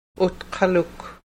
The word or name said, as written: utqaluk